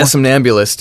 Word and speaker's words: Somnambulist: When was it when like Somnambulist? How do you say that? – Yeah, Somnambulist. Yeah, that was a pretty big hit for him on Top 40.